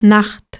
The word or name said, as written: axşam